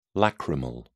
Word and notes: lacrimal: British English

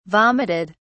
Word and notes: vomited: British English

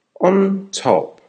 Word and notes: on top: American English

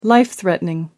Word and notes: life threatening: American English

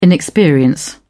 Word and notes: inexperience: British English

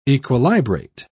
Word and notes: equilibrate: British English

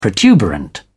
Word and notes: protuberant: British English

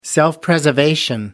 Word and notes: self-preservation: American English

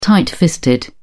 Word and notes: tight fisted: British English

